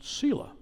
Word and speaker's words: Selah: As you read this Psalm 62, you notice that verses 4 and 8 end with a word that is pronounced Selah, S-E-L-A-H.